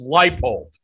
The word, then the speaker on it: Leipold: The guy's name is Leipold.